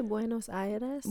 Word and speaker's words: Buenos Aires: How do you say Buenos Aires? – Buenos Aires. – Aires. – Aires. Uh-huh.